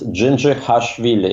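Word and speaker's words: Dzindzichashvili: Yeah, his last name is very difficult to pronounce. It's Gingerhashvili. It's a Soviet Georgian last name.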